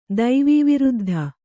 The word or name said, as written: ದೈವೀವಿರುದ್ಧ